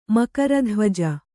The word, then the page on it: ಮಕರಧ್ವಜ: kannada